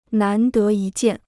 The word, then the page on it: 难得一见: nán dé yī jiàn